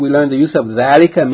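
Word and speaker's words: dhalika (ذَٰلِكَ): Note, number one, dhalika is pronounced dhalika, as though the dhal, fatha is followed by aleph, but it is written without the aleph. But in sound, you have to pronounce it with a long elongation of the vowel, dhalika.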